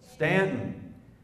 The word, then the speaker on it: Staunton: Is it Stanton or is it Staunton? Stanton.